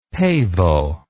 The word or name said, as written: pavo